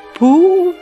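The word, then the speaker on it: Пугачёв: Пухачев. Я неверно произношу. Пугачев.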